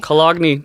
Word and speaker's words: Cologne: Cologne, Germany. – That's how it's pronounced. We're sorry, Germans.